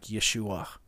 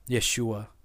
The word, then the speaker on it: Yeshua: His name, closer pronunciation to the way it would be have been pronounced in his time is something more like Yeshua. Yeshua.